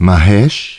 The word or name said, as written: Mahesh